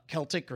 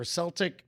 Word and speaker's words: Celtic: But, but in the chat, somebody mentioned homonyms, and most of these are able to be pronounced two different ways. Celtic or Celtic, wind wind.